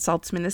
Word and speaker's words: Saltzman: That's R-E-B-E-K-A-H. And Saltzman is spelled S-A-L-T-Z-M-A-N.